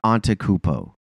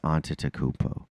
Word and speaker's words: Antetokounmpo: And also, Mike Breen, it's not Antetokounmpo. Like stop trying to be like you know it. You just drop an entire syllable, Antetokounmpo. Like. It's Antetokounmpo.